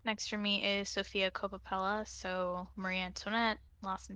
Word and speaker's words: Coppola: Is it Coppola? Yeah. It's oh, like it's like Francis well. Ford Coppola.